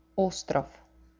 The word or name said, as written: ostrov